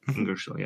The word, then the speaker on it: Ingersoll: Ingersoll, am I pronouncing this correctly? Ingersoll, yeah.